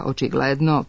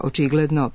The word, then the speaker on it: očigledno: Stoga, umesto verovatno više spratnica, očigledno, prevashodno, radijator, svojevrstan, svakodnevno, treba reći verovatno više spratnica, očigledno, prevashodno, radijator, svojevrstan, svakodnevno. Akcenat će se na isti način prenositi i u stranim rečima koje se češće javljaju sa silaznim akcentom unutar reči.